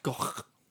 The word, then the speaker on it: Gough: And it is pronounced Gough.